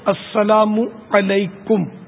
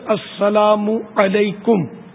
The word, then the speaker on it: السلام علیکم: تو یہ صحیح تلفظ اس کا یہ السلام علیکم میم پر پیش ہے,